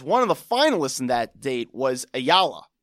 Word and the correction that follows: Aliyah: I think that's how you pronounce her name, right? – No, it's Aliyah, right? It's Aliyah.